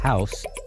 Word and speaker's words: Jaus: House, they're from Mexico City, so it's spelled J-A-U-S, House.